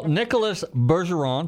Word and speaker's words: Nicholas Bergeron: Well, Nicholas Bergeron, did I pronounce it right that time? – Absolutely correct.